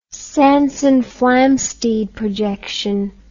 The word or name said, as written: Sanson-Flamsteed projection